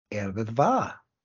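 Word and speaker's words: Yr Wyddfa: No, we call, so the correct pronunciation allegedly is Erbivá.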